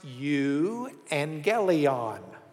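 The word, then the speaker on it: euangelion (εὐαγγέλιον): That Greek word that's up there, if I bring it down here, is pronounced euangelion. Euangelion. EU makes a U sound.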